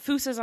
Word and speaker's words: fossa: So when it comes to these, they've got fusas on the ground, which, by the way, I've been pronouncing fossa my whole life, so I apologize retroactively for that. – Well, yeah, so all the O's in Malagasy words are pronounced ooh.